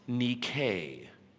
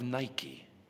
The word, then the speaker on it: Nike: In Greek, her name would be pronounced Nike. Nike in Greek translates to something like overcome, conquer, win. You might know her better by the anglicized version of Nike.